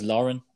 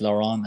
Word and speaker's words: Lauren: So number seven with 12 points is, if you pronounce it, the way I pronounce it is Lauren. Probably in French, it's Laurent. And probably in Dublin, it's Lurden.